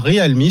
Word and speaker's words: Realme: Alors, Realme, ça s'écrit R-E-A-L-M-E, Real comme Realme.